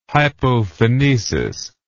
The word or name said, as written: hypophonesis